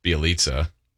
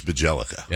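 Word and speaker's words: Bjelica: Trying to figure out how to pronounce Bielitsa. – Nemanja. – I believe it's Bajelica. – Yeah, okay.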